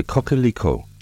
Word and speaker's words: coquelicot: Poppy flowers in French are pronounced fleur de coquelicot and spelled fleur, F-L-E-U-R-S, de, coquelicot, C-O-Q-U-E-L-I-C-O-T-S. Poppy seeds are pronounced graine de pavot and spelled graine, G-R-A-I-N-E-S, de, pavot, P-A-V-O-T.